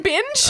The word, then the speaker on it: Bynge: But Bing is spelt B-Y-N-G-E. – Binge or binge, you know, you could read it either way, but it's Bing.